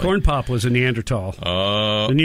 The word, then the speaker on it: Neanderthal: There's something special about a man older than dirt calling me a Neanderthal. Really, me? – You pronounce it right.